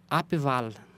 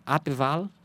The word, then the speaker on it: Abwahl: Na Alemanha, existe o Abwahl. É assim mesmo que se pronuncia. Abwahl, que é parecido com o recall.